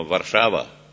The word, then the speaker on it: Warszawa: como ellos dicen en su idioma, Warszawa, no Varsovia, como nosotros pronunciamos en español.